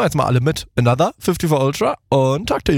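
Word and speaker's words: Anotr: Und ich weiß nicht, ob ich den Namen des Artists richtig ausspreche, aber Anotre featuring 54 Ultra. – Ich glaube, Another wieder ausgesprochen.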